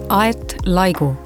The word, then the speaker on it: Laigu: My name is Laigu.